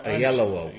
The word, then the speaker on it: Oyelowo: David Oyelowo for Selma. – Oyelowo.